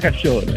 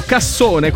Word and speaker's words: cassone: Allora, sai che io avevo letto cassone, quindi non è cassone, ma è cascione. – Poi dopo gli devi dare la pronuncia, con la doppia S, però gli devi dare la pronuncia, cascione.